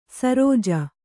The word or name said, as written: ಶರಜಾ